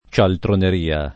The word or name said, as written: cialtroneria